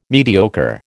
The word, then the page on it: mediocre: American English